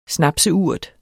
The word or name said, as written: snapseurt